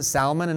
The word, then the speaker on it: Salmon: Why did I pronounce it Salmon and not Salmon? I just didn't want him to sound like a fish this morning. I don't know what the proper pronunciation is.